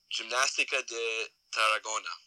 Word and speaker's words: Gimnástica de Tarragona: It was a game between Huesca and this other team, which I'm not going to try and pronounce. – Just come on. What is it? – Gimnástica de Tarragona. – Oh, there you go.